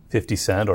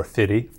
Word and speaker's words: 50 Cent: Now, tell me, how do we pronounce it? – 50 Cent or Fitty.